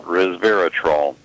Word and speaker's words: resveratrol: We were talking about, I think it's pronounced resveratrol. – Yeah, resveratrol.